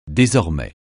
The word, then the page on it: désormais: francais